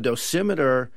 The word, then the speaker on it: dosimeter: I mean, one thing you talk about in the book is your is it dosimeter? Is that how you say it? – Yes. – You carry with you? – Yeah, that's right. A dosimeter you put, you know, in your pocket or something, and that measures your accrued dosage.